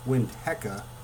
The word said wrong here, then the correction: Winnetka: It's actually Winnetka not Winteka, Winetka.